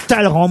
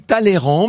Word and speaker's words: Talleyrand: Vous, vous dites toujours Talleyrand. Moi, je dis Talleyrand. Qu'est-ce qu'on doit dire? – Ça s'écrit Talleyrand, mais ça se prononce Talleyrand.